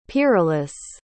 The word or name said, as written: pyralis